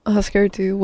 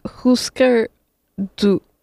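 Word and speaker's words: Hüsker Dü: That is a song that has also been covered by Green Day, and so it's Husker Du was a... Well, that's not how you pronounce it. It's like Husker Du, because it's a U, but it has the little two dots.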